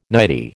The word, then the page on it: nightie: American English